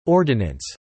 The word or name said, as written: ordinance